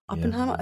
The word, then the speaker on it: Oppenheimer: Oppenheimer? Yeah. I don't know if I'm saying that right. – You are saying it right.